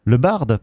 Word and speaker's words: Le Bard: mais le dernier artiste français c'est Le Bar. – Le Bard, pardon. – Ah, le D se prononce.